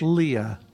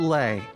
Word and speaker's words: Leah: How do you pronounce L-E-A-H? – Leah. – Not it is Leah. – Not Leigh. – Leigh would have an I in there. And a G. – L-E-I.